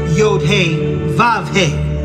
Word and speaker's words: Yod-Heh-Vav-Heh: Yod-Heh-Wah-Heh. Or as some with their pronunciation, Yod-Heh-Vav-Heh.